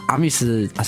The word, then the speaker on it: Amis: 会比较适当，就会讲生活，或者是它有更特别的地方，就我们就下了一个名字叫 Army's Life，嗯，就是阿阿密斯是阿美族的阿美族语发音，嗯，自己讲我们讲阿密斯。